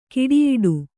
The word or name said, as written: ಗೆಡೆಯಾಟ